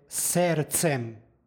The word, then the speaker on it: sercem: psalma pročitana je sercem, kao i u srpskom jeziku, gdje imamo srce, ovdje imamo serce, odnosno sercem, bez izgovornog D.